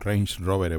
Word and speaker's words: Range Rover: Al final el diseño, por ejemplo, de un Rolex Submariner o de una Leica o el diseño del Ranger Rover Evoque, pues Range, perdón, Range Rover Evoque, no se lo he dicho bien, va, da igual.